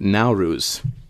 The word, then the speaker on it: Nowruz: So there's it's called I might be pronouncing this wrong, but Nowruz and the Persian New Year. – I believe you're pronouncing it right.